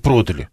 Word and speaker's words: продали: В любом случае, другие словари действительно говорят, продали можно, но вот разговорно. А так-то, конечно, продали.